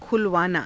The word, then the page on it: khulavaanaa: Hindi